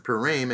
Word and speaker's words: Purim: That's the right way to pronounce it, Purim.